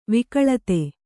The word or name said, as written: ವಿಕಳತೆ